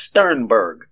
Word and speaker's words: Sternberg: The writer of the article is Bill Sternberg. That's Sternberg. His last name is spelled S-T-E-R-N-B-E-R-G.